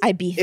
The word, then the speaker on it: Ibiza: It's actually pronounced Ibiza. – It is Ibiza because it's a Spanish word and Z's are... That's why.